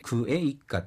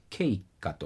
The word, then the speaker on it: ケイッカ: だから、この場合は他動詞のエイッカを使わなきゃいけないということで、クエイッカっていうのはケイッカと発音されます。